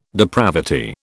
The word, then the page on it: depravity: British English